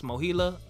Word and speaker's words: MOHELA: Mine got moved to, I believe it's pronounced Mohela, M-O-H-E-L-A.